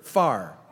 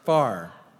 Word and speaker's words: fire: Now in the South, it's a single syllable word. Far. Far. It's not a distance. It's that glowing hot stuff.